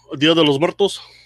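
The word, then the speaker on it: Día de los Muertos: Oh, Dia de los Muertos.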